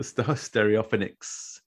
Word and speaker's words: Stereophonics: The Stereophonics.